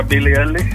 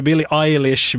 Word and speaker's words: Billie Eilish: Billie Eilish, sa du Billie Eilish. Vi är godkänt för det. – Vi är godkänt. Ja. Uttal ska inte vara det som fäller någon här. – Nej, det var alldeles korrekt med andra ord.